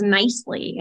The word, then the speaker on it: Knisely: So the voice pronunciation, especially for yourself, myself, my married last name, although I didn't take it, but I use it, is Knisely. And I get Knisely.